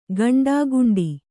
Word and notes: ಗುಂಡಾಗುಂಡಿ: guṇḍāguṇḍi

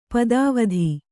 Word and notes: ಪದಾವಧಿ: padāvadhi; kannada

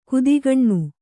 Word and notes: ಕುದಿಗಣ್ಣು: kudigaṇṇu

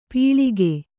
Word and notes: ಪೀಳಿಗೆ: pīḷige

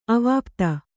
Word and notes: ಅವಾಪ್ತ: Kannada

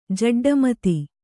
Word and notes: ಜಡ್ಡಮತಿ: Kannada

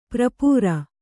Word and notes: ಪರಿಪೂರ: Kannada

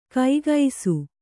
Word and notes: ಕೈಗೈಸು: kaigaisu

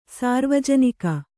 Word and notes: ಸಾರ್ವಜನಿಕ: sārvajanika

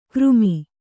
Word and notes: ಗರಮ್: kannada

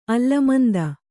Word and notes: ಅಲ್ಲಮಂದ: allamanda

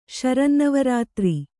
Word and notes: ಶರನ್ನವರಾತ್ರಿ: śarannavarātri

